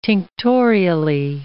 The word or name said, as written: tinctorially